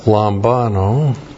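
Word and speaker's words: lambano (λαμβάνω): And then we have lambano. L-A-M-B-A-N, long O, lambano.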